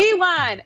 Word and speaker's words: Iwan: I was saying his name wrong too, by the way. I heard that. – Iwan Kutalaba. The broadcast. Yep. Iwan. It looks like Ian.